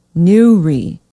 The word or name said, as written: newry